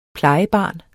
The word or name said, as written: plejebarn